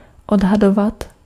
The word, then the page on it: estimer: France; IPA: [ɛs.ti.me]